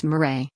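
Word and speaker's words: mere: Here's a portrait of one celebrated 19th century rangatira, or chief, holding his ceremonial mirror, pronounced mirai, of office, a flattened club which is quite sharp at the broad end, like an axe head.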